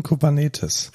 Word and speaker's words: Kubernetes: Und die Betonung liegt in Kubernetes.